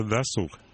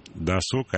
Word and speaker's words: досуг: Тоже досуг. Михаил, спасибо за правильное ударение. Досуг, а не досуг.